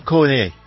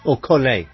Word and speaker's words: Collet: Oh, no, it's directed by Jean Collet. Collet. – I don't think they pronounce the T.